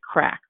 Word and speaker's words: craic: The Irish, now you say it, the Irish have a word for people having good fun and conversations, and the word is crack, which is spelled C-R-A-I-C.